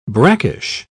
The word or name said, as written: brackish